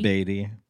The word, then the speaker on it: Beatty: Beatty. It's spelled like Warren Beatty. – Okay. – B-A-A-T-T-Y.